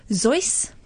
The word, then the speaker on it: Seuss: Um, but in traditional pronunciation of that, it should actually be Zeus. Dr. Zeus. – Dr. Zeus. Dr. Zeus. We're saying it wrong. We're saying Dr. Seuss. It's Dr. Zeus. Um, that's the traditional one. But of course, as he got more and more famous, everyone mispronounced it. And he's like, fine, we'll go with Zeus, uh, Seuss.